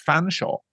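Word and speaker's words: Featherstonehaugh: You know, I mean, for years I didn't know that the English name Featheringstone Hall is pronounced Fanshawe.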